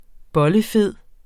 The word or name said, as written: bollefed